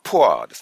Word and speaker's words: pour: 他のは、ポアオイルオンダファイヤー。 – フォアオイル。フォアってその数字のフォーですか？あ、ポアですね。 – プア。 – ポア。ちょっと待ってくださいね。発音が。ポア、ポア、 P-O-U-R。